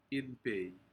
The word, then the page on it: inpci: Aneityum